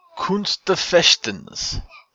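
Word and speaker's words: Kunst des Fechtens: It's K-U-N-S-T, next word D-E-S, last is F-E-C-H-T-E-N-S. – Looks like it. Kunst der Fechtens. – Yeah, it's German.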